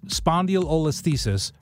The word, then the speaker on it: spondylolisthesis: In fact, I used to depose a doctor one time, and he had a sign on his desk, and the sign said, If you cannot properly pronounce spondylolisthesis, do not ask me about it. – Really? – Yes. – So since you can say it, did you ask him? – I did. And he said, you said that correctly.